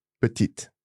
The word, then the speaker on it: petite: So, for example, the masculine form of small is petit. – Exact. – The feminine form would be? – Petite. – Petite. Now, I'm sure I've heard petite. – Again, some regions might overpronounce, in my opinion, pronounce a final E. But other than that, it's just the T that's pronounced here.